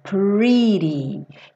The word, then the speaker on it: pretty: Cuidado aqui, ó. Pretty. Não pode ser pretty. Porque a gente tem um R e o R em inglês é enrolado, né? Como em porta, per, né? Então, pre. E aí aqui, como tá pintadinho de azul, ri-ri-ri, ri-ri-ri. Igual na palavra guri, pretty, tá?